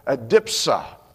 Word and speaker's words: adipsia: And by the way, that's spelled A-D-I-P-S-I-A. So it looks more like adipsia, but that's not the way the pronunciation guide on the internet says it.